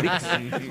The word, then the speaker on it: disc: Compact Discs. No, Dix no. D-I-X no es. Es D-I-S-C. – Claro, disc. – Claro, de disco.